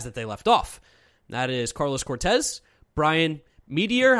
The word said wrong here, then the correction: Metoyer: I don't know how to pronounce his name. – Metoyer. – Metoyer and Carlos Rincon, who could all get swept up.